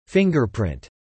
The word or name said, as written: fingerprint